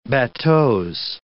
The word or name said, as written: bateaux